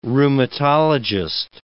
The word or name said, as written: rheumatologist